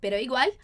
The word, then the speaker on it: igual: Muito cuidado com a pronúncia, não é igual a gente fala no português. Igual. Sabe? Então, igual, tá? Com a linguinha aqui em cima. Pero igual.